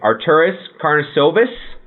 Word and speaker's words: Arturas Karnisovas: Arturas Karnisovas? – Yes, I believe that's correct.